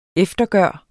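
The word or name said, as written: EFTERGØRES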